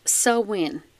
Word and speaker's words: Samhain: So, without further ado, pronounced Samhain,